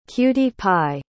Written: cutie-pie

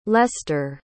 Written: lester

